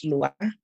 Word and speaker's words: Lwa: Well, for one, it's pronounced Loa. It may be spelled L-O-A, but you should pronounce it like L-W-A, you know?